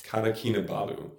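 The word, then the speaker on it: Kota Kinabalu: But you started in, let me see if I can pronounce this, Kata Kinabalu. – Yes. – Kata Kinabalu.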